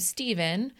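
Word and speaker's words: Stephan: In episode one, I mentioned that Jim and Marceline had a child named Stephen, spelled like Stefan with an A, but pronounced Stephen.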